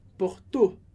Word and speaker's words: Porto: Presta atenção, tem uma diferença importante com relação ao português, que a sílaba forte no francês é sempre a última. Então, Porto. O. Com o bem mais forte.